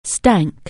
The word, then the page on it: stink: anglais